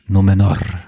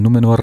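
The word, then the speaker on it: Númenor: Numenor. Numenor. – Das reicht schon. Du, du musst nicht rollen. Du musst nicht rollen, es reicht. Im Deutschen rollen sie auch nicht.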